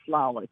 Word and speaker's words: Lalich: And by the way, it's pronounced Lelich.